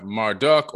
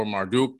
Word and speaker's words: Marduk: And then you have Marduk or Marduk, depending on how you want to pronounce it.